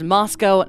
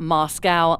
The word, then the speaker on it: Moscow: Yes, it is pronounced Moscow, not Moscow, like in Russia.